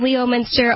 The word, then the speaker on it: Leominster: What do you mean, Leominster? Oh, that word that's spelled Leominster, but we just say Leominster?